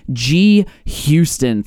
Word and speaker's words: gHouston: I have with me G. Houston.